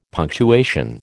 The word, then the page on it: punctuation: American English